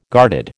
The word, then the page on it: guarded: American English